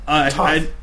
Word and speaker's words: Thoth: Thoth. Yeah, it's like it's spelled T-H-O-T-H.